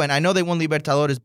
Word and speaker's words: Libertadores: Libertadores.